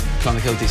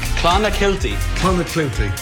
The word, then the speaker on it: Clonakilty: Irish tea bags or Clonakilty sausages from Cork. – Clonakilty, thank you.